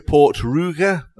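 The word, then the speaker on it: Port Ruighe: So it's Talisker Port Ruige. It's spelt R-U-I-G-H-E.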